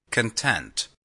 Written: content

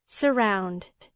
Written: surround

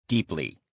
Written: deeply

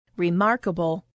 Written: remarkable

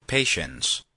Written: patience

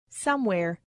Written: somewhere

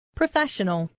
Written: professional

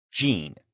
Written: gene

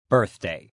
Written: birthday

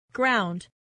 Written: ground

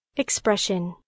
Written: expression

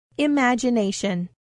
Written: imagination

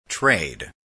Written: trade

